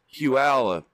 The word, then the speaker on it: Puyallup: It's not spelled Puyallup. It's pronounced Puyallup. – Right. – It's spelled Puyallup. – Puyallup. – P-U-Y-A-L-L-U-P.